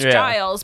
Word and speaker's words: Giles: Where, like, because we know, obviously, that it's a soft G, so it's Giles.